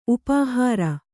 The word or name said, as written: ಉಪಹಾರ